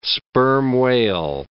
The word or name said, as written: sperm whale